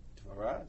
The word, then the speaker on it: Devorah: Devorah is certainly a classic Hebrew word. Devorah is a V.